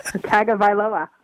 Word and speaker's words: Tagovailoa: Well, Governor, how do you pronounce his last name, Tua? – I don't know, and you know I don't know. – Tagovailoa. – There we go.